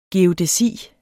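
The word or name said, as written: GEODÆSI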